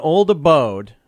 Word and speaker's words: Old Abode: Usually people call us Old Adobe, so it's nice to hear somebody get the name right.